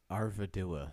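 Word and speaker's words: Arvedui: Círdan's sailors eventually unable to rescue Arduvii. How do you say it? I already forgot. – Arvidua. – Arvidua. – Yeah.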